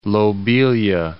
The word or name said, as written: lobelia